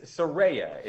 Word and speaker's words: Saraya: She just wrote the pronunciation in a tweet, I think. It's Saraya is, I believe, how you say her.